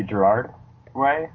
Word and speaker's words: Gerard Way: So, real quick, the only reason I know, did you say Gerard Way? Is that how you pronounce that name? – Yeah.